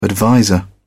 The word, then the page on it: adviser: British English